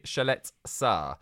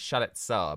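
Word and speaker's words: Duje Ćaleta-Car: But anyway, instead, I'm bringing to the table 23-year-old centre-back Duje Chalet-Sar. It's actually looks like it's spelled Kaletakar, but I've been doing my research this morning and it's Chalet-Sar, apparently.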